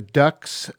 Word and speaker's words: Duhks: I began tonight's show with a song by a French-Canadian band, The Ducks, spelled D-U-H-K-S. We listened to the song The Leather-Winged Bat from their 2002 album, Your Daughters and Your Sons. And The Ducks were a Canadian folk fusion band that formed in Winnipeg in 2002 and disbanded in 2016.